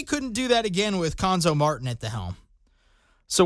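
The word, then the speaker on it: Cuonzo: Last year, it was Quanzo Martin. Conzo Martin, excuse me. Got to say his name right.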